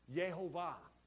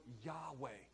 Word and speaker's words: Yahweh: Yehovah, Jehovah, is the mispronunciation of the word Yahweh because it was so holy they would not even pronounce it correctly.